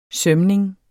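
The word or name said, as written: sømning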